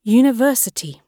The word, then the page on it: university: British English